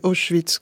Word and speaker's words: Auschwitz: Et son invitée, qui était une auteure juive, l'a reprise de façon très dure, véhémente, en lui disant « Ce n'est pas comme ça que ça se prononce, c'est Auschwitz qu'on dit.